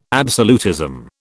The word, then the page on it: absolutism: British English